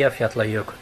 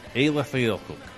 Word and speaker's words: Eyjafjallajökull: Looks like Ijafelekjakulu. – Yeah, they seem to like. Lazily forget half the syllables, and it just sounds wonderful when they say Eyjafjallajökull. – Eyjafjallajökull.